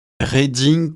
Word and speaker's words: Reading: Reading se prononce comme reading, liseur, studieux.